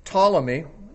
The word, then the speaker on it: Ptolemy: And then Ptolemy, spelled with a silent P, P-T-O-L-E-M-Y, Ptolemy reigned in Egypt.